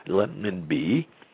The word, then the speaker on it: renminbi: Now, what we expect to happen is that the Chinese renminbi, as it's written, or more correctly pronounced, renminbi, or maybe the yuan, since renminbi is so hard to pronounce, and I'm having trouble today, it'll be added to the major reserve currencies.